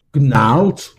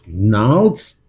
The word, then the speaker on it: Gnarls: Das ist Gnarls, Gnarls, Gnarls. Nur das G spricht man nicht.